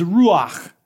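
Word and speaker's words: ruach (רוח): When it comes to the word spirit, the Hebrew word for spirit is ruach. Yeah, I'm not trying to hawk a loogie. It's just, it's how you say it, okay?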